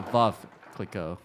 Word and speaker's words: Veuve Clicquot: So I always say it Veuve Clicquot. Is that the right way to say this? – Actually, it's a Veuve Clicquot. Like we like to, in our trainings, we like to say Veuve like love.